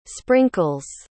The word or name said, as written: sprinkles